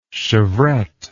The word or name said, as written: chevrette